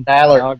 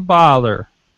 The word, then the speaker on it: Balor: His arm is broke, and you cannot be polite enough to pronounce his name correctly. – Finn Balor. – Balor. – Sorg. It's like Team Valor.